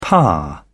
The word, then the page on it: par: British English